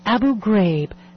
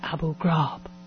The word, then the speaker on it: Abu Ghraib: And please note, for the sake of the voicemail system, it is pronounced Abu Ghraib, not Abu Ghraib.